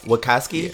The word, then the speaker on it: Wachowski: Wachowski.